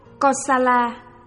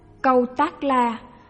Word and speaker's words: Kosala: Kiều tắc la, phiên âm từ Phạn ngữ là kosala, cũng đọc là câu tác la, câu tác di la, là một nước lớn ở Ấn Độ vào thời Đức Phật, kinh thành là Xá Vệ.